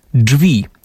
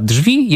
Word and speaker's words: drzwi: I tak na przykład we wspomnianym słowniku poprawnej polszczyzny znajdziemy informację, że słowo drzwi należy wymawiać wyłącznie jako drzwi, tak jak dżem, a wymowa drzwi jest niepoprawna. Ten zapis to zwyczajna pomyłka.